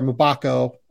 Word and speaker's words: Mgbako: Um, but yeah, like, look, Proctor could end up in a situation where he's playing next to Jared McCain, Jaden Schutt, like Mackenzie Mbakpo, uh, or Mgbako. Uh, yeah, sorry. Uh, realized I said that wrong the first time.